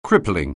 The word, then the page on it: crippling: anglais